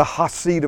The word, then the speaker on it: Hesed: and in that song he talks about the Hasid of God. That's spelled H-E-S-E-D.